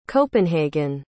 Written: copenhagen

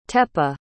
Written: tepe

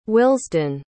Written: willesden

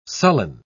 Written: sullen

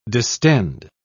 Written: distend

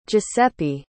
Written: giuseppe

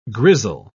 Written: grizzle

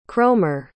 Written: cromer

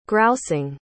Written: grousing

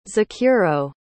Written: Zakuro

